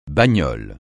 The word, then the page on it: bagnole: francais